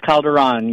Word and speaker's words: Calderon: Is it Calderon or Calderon? – It's Calderon. You said it right. Calderon.